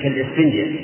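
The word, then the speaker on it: كالإسفنجة: أنا أحكيها ألف فيها همزة كالسفنجة، هكذا ننطق بها.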